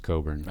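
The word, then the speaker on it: Kofi Cockburn: We said his name wrong, apparently, the entire last episode, but how in the world would you ever imagine that his name is pronounced Coburn? – I don't know.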